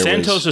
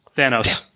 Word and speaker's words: Thanos: If you could Thanos style snap your fingers and go back to a time where there Santos was... Santos is Thanos' younger brother, less successful, but equally as villainous. Continue. – Did I pronounce it wrong? – Thanos.